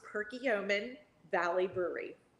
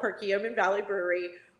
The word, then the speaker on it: Perkiomen Valley Brewery: It's Perkiomen Valley Brewery, just like it's spelled, Perkiomen Valley Brewery.